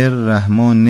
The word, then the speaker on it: الرحمن: ببینید من دقت کردم بسم الله الرحمن الرحیم رو که میگفتید حرف را تشدیدش مشخص نشد، یعنی گفتید بسم الله الرحمن الرحیم به این شکل، بسم الله الرحمن الرحیم، بسم الله الرحمن الرحیم، یعنی حرف را باید مشدد تلفظ بشه، رو دقت بکنید.